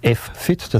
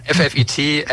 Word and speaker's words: FFIT: FFIT, F-Fit, Fit, also alle Möglichkeiten sind da durchaus ähm, akzeptiert. Das können wir aussprechen, wie wir wollen.